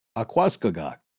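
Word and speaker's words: Aquascogoc: In this case, it's spelled A-Q-U-A, so like aqua, and then C-O-G-O-C. So to me, Akwaskagak.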